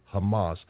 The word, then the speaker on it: Hamas: By the way, I think it's 2554 or 2555, and the Strong Exhaustive Concordance, it's spelled C-H-A-M-A-S. In the Hebrew, it means violence. Hamas. Violence. The transliteration is the C is silent. C-H-A-M-A-S. They don't use the C. You just hear them say Hamas.